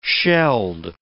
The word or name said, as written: shelled